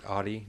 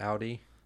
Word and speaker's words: Audi: An Audi, you pronounce it Audi, not Audi.